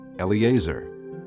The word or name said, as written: Eleazar